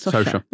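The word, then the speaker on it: Socha: Sosha.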